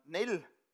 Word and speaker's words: Neil: And Neil is spelled N-E-I-L, one L, okay? When you write me all your Christmas cards and give me all the gifts, it's N-E-I-L. But it also can be spelled N-E-A-L.